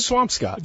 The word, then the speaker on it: Swampscott: Todd McShay, the pride of Squamscott, Massachusetts. Is that accurate? Is that where you're from? – Greeny, say it right, man. – What is it? – Swampscott.